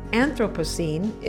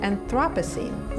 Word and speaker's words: Anthropocene: She says that Anthropocene is the preferred pronunciation rather than Anthropocene.